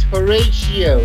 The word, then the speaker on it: Horatio: It's Horatio. It's like English.